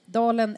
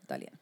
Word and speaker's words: Dahlén: Ja, att hon uttalar det. Dalen eller Dahlén. Vi tänkte Dahlén.